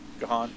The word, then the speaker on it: Gahan: I gotta mention Dave Gahan, I don't know how to pronounce his last name. Gahan? Okay.